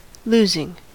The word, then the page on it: losing: American English